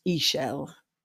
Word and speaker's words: Ixchel: Her name is actually pronounced Ixchel, the moon goddess.